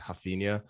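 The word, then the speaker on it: Raphinha: I think something that was interesting is that we saw both Dembele and Rafinha, or the Brazilian way of saying, I think it's Rafinha. – Oh, is it really? Okay. – Yeah. I think that's the Portuguese way of saying the R is like pronounced as an H.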